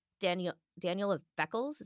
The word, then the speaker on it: Daniel of Beccles: So because of that, I thought we should do Daniel of Beccles. Is that how you say his name? – Yeah.